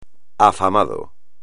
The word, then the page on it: afamado: espagnol